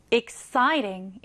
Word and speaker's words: exciting: 강세는요, 여기에 넣어주시면 돼요. exciting 이렇게요.